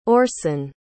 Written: orsin

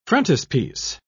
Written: frontispiece

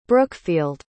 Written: brookfield

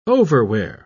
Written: overwear

